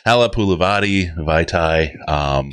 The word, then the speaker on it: Halapoulivaati Vaitai: Halepulivati Vaitai. – Dude, you what? Said that right. – Oh, yeah. What? – I'm impressed. For a guy who can't say Amani Oruwariye, you did very well there.